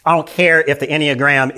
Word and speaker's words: Enneagram: The enneagrams. Enneagrams. How was that? Enneagram. That's how you pronounce.